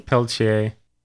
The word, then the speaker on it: Peltier: How do you say her last name? – Peltier.